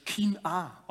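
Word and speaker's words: Kina (קנאה): Im Hebräischen steht da ein Wort, das würde man auf Hebräisch Kina aussprechen.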